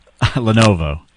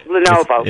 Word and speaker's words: Lenovo: Levelo? – Lenovo. – Oh, right, yeah, Lenovo.